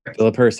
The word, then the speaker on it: Philip Hurst: Today, I have with us Philip Hurst. Is that how you say your last name? – Philip Hurst. That's correct. – Philip Hurst.